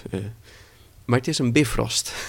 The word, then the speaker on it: Bifrost: En nou ja, het wordt ook in Marvel wordt het zo uitgesproken, Bifrost.